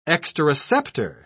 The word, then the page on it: exteroceptor: British English